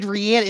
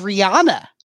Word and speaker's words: Rihanna: You said Rihanna. It's Rihanna. That's her name, dude.